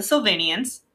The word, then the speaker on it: Sylvanians: So this one is called the Sylvanians. – The Sylvanians. – Sylvanians. So it's like Sullivans, but they pronounce it very edgy.